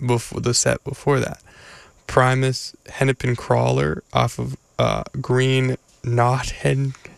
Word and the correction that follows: Naugahyde: It says on the CD how to pronounce it. Wait. Okay, hold up. Green Naugahyde. Oh, I was not close.